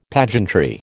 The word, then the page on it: pageantry: American English